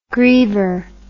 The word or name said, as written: griever